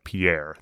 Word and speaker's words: Pierre: And that's Pierre, but it's spelled like Pierre. I always think of it P I E R R E shale.